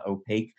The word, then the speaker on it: Opaq: O-P-A-Q is how it's spelled. It's pronounced Opaque.